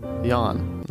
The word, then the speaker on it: Jan: My favorite was Yawn, spelled J-A-N, which is pronounced Yawn, and I spelled it out for me.